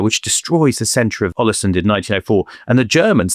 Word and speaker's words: Ålesund: His family are actually big in the business of exporting dried and salted fish in a town called Ålesund, which I know is the correct pronunciation. I visited last year, went there on a family holiday to Ålesund, absolutely gorgeous town up on the Norwegian coast.